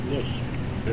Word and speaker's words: Glusk: And I can't give you the spelling, but it's pronounced Lusk. – And probably spelled G-L-U-S-K.